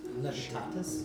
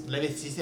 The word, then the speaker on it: Levistus: And in that moment before death, the archdevil Levistus, am I saying that right? – Mm-hmm. Sure. – Levitatus? – Levistus, yeah. Oh, shush.